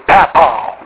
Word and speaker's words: papaw: If you go into the Appalachians, it's papa. It's spelled P-A-P-A-W with accent on the W, except Appalachian people don't put accents on. But trust me, they say it that way.